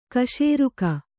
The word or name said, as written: ಕ್ಷೀರಕ